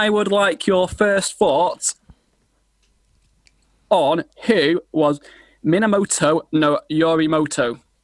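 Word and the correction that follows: Minamoto no Yoritomo (源頼朝): I hope I pronounced that right, but. – Pardon. – Minamoto no Yoritomoto. – Can you say it one more time, please? I'm going to write it down. – Yeah, I'm trying to write it. Yeah. – Minamoto no Yoritomo. I think I said that like three different times now.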